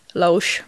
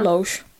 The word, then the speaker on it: Loesch: Mitä se lausutaan? – Siis mitä kirjaimia siinä on? L-O-E-S-C-H. – Niin, Loesch varmaan. – Loesch.